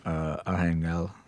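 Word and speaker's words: Arcángel: Everything in her life is controlled by Arhangel, which is spelled like Archangel. The Spanish pronunciation is exactly as Gongli pronounces it here.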